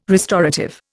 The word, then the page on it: restorative: Indian